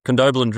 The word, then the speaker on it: Condobolin: I'm never going to be able to pronounce that correctly. In my entire life, I've been out there and you retired. Condobolin drilling. – Oh, you got it right there.